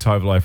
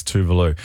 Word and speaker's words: Tove Lo: I said Tove Lo for ages, but when I got right into her, I realized it's Tuvalu. This is so good. It's brand new from Tuvalu.